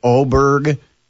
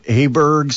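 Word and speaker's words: Åberg: I heard some announcers that did say Aberg, mm-hmm. – and then some that were saying Oberg. And apparently it's the actual Swiss pronunciation is Oberg. – Yeah, it's one of those. – But in America, it's accepted to pronounce it Aberg.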